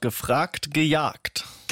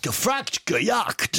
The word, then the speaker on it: Gefragt, Gejagt: It's called Gefragt, Gejagt. – Gefragt, Gejagt. – Yes, it means asked and chased, but it rhymes in German.